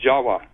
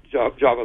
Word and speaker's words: Java: The word in Java, the island of Java, you know, is pronounced Jawa, actually. Indonesians will pronounce it Jawa, not Java like we do.